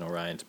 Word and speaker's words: Orion: It's spelled like Orion, and their logo has like the three stars in Orion's belt, but you say it Orion because it's Japan. That's how you say it.